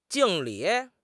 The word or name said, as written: 敬礼